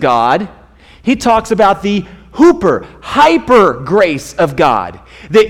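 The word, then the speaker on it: hooper (ὑπέρ): In the Greek, it's pronounced hooper, and it means above and beyond and so much more than what you expect.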